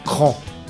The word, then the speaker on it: Krah: C'est KRA, ça s'écrit K-R-A-H, mais selon le mec qui nous a écrit un mail, ça se prononce CRAN. Donc encore un groupe qui s'appelle CRAN, c'est du post-punk, c'est sorti en janvier 2021, et on va écouter le titre qui s'appelle CRAN également.